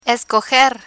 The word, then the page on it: escoger: spanish